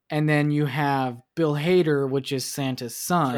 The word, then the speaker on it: Hader: I don't know if he pronounces it Hader or Hatter. – Oh, yeah, it is Hader.